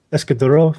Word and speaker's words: Escudero: Ray, and the key phrase you're searching for, Villa, how do you pronounce that? – Escudero? – Escudero.